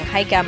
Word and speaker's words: Heike: He's at Joel Book on Twitter, and I'm at Young Heike. My name is spelled H-E-I-K-E.